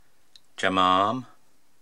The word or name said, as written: Jamaam